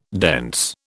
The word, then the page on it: dense: British English